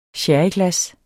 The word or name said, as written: SHERRYGLAS